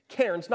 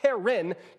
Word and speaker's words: cairns: Cairns, not Karen. Cairns, one syllable. Karen, two syllables.